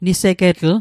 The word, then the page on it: nisegetl: Mi'gmaq